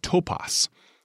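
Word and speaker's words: topos (τόπος): Now, that word place, it has at its root a word pronounced topos.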